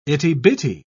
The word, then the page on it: itty-bitty: British English